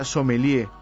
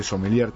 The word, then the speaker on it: sommelier: Sommelier, que se escribe con doble M y con R si es singular y con S si es plural, se pronuncia sommelier, van a escuchar seguramente sommelier también.